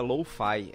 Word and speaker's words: lo-fi: coisa, que eu utilizo uma das estratégias, algumas das estratégias, é da música lo-fi. É lo-fi que se pronuncia? – Lo-fi, isso.